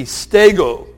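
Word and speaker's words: stego (στέγω): It's the Greek word stego. Sort of sounds like the word Lego, but it's S-T, stego.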